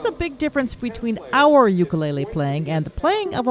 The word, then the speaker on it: ukulele: It's pronounced ukulele, and it's two Native Hawaiian words, uku, which means flee, and lele, which means jumping. So it's the direct translation is jumping flee, ukulele.